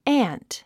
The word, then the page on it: aunt: ǽnt; アァント